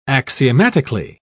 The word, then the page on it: axiomatically: British English